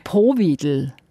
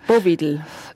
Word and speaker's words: Powidl: Man backt den Dampfnudeln mit, jetzt weiss ich nicht, ob ich es richtig ausspreche, Powidl. – Das ist richtig. Eine typisch österreichische Spezialität, eine Zwetschgenmus. Bovidl.